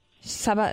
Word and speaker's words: Saba: Saba and Safta.